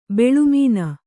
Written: ಬೆಳುಮೀನ

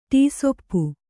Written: ಟೀಸೊಪ್ಪು

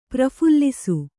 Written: ಪ್ರಫುಲ್ಲಿಸು

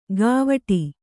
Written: ಗಾವಠಿ

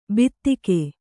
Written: ಬಿತ್ತಿಗೆ

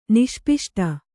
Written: ನಿಷ್ಪಿಷ್ಟ

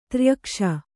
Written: ತ್ರ್ಯಕ್ಷ

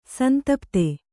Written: ಸಂತಪ್ತ

